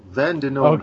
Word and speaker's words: Vandennieuwenhof: You added an extra syllable. Vandenhoff.